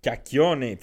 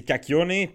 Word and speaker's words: Kakione: Je dis cac parce que les gens comme toi qui ne savent pas comment le prononcer, je fais juste simplifier la vie, tu sais. Fait que non, mais c'est Kakione.